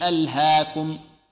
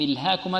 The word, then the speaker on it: ألهاكم: ألهاكم هذا خطأ يقع فيه بعض الطلاب بارك الله فيكم ألهاكم عندنا هنا إغلاب ما هو الإغلاب يعني ياء تنطق ألف وترسم ماذا ياء